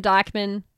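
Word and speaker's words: Dijkman: So it's at Megan Dykman.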